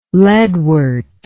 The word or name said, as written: leadwort